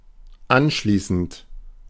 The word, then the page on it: suivant: France; IPA: [sɥi.vɑ̃]